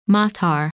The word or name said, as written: Matar